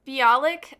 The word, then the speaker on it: Bialik: I'm probably pronouncing her name wrong. I apologize. – I think it's Bialik. – Bialik? Okay, Bialik. – Yeah, something like that.